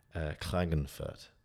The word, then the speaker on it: Klagenfurt: And it's from TEDx Klagenfurt.